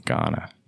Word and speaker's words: Ghana: Dad's from Ghana, though. – Ghana. – So, Ghana. Yeah. – Not here, Ghana. – Not here, Ghana. Yeah.